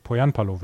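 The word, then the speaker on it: Pohjanpalo: Ich habe irgendwann mal gehört, dass die Finnen auf der ersten Silbe immer betonen. Dann hieß es Poyanpalo.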